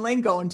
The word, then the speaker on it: Langone: So we're talking to Dr. Karen Langone today.